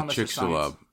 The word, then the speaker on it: Chicxulub: Oh, the Chicxulub. – Chicxulub? – Yeah. – Chicxulub asteroid.